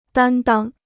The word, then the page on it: 担当: dān dāng